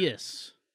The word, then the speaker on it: Urias: I think Urias, am I saying that right? – Yeah. – I think he's the highest ceiling. And I think Eric, Urias.